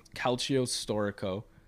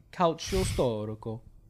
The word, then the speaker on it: Calcio Storico: It's called Calcio Storico, and it's like... – Good pronunciation. – Calcio Storico.